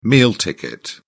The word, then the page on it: meal ticket: British English